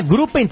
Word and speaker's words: Gruppen: A Grupen tá com a gente. A Grupen tá com a gente. Se escreve G-R-U-P-P-E-N.